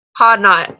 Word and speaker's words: Hodnot: And Ainsley, I don't even know how to say your name properly. Ainsley, I'm not even going to try. – Hodnot. – Oh, that's not that hard. Hodnot.